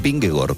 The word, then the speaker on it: Vingegaard: Es decir, el ciclista danés Vingegaard es Vingegor, por raro que suene.